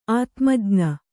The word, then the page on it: ಆತ್ಮಜ್ಞ: ātmajña